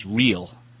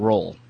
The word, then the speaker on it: Reel: I don't want to take any responsibility, but I'm told that it's pronounced Reel. And not Roll. Jeff Reel.